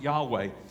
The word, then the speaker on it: Yahweh: It's YHWH, or when you put some vowels in, we pronounce it Yahweh.